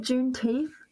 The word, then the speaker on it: Juneteenth: Sorry, did I say that right? Juneteenth? My apologies. Oh, thank goodness. – So, you said it right, totally.